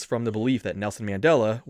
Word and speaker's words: Mandela: Mandela.